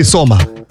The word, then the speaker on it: сома: Александр пишет шашлык из африканского сама или сома? Сома. Сом это рыба? Да. Правильно? Да, африканский сом а, такой. – А, а если сама, где ударение-то? – Сома. Да? – Да.